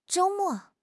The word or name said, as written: zhou1 mo4